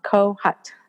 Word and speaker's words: Kohut: My last name is K as in King, O, H, U as in ukulele, and then T as in Tom, Kohut.